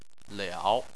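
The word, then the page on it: liao: tone 3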